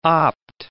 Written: opt

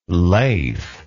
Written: Laith

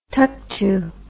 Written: tuktu

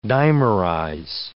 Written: dimerize

